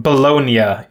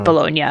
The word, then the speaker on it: Bologna: Bologna. Um, mm-hmm. So then there was a whole back and forth about whether making the N say nya instead of nah is uh, in fact a silent G um, so yeah, that's um, it's a it's a worthy question.